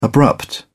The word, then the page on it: abrupt: British English